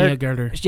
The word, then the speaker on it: Agutter: She was in Jenny Agutter.